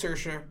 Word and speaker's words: Saoirse: Yeah, it is Saoirse Ronan.